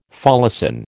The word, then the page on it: folacin: American English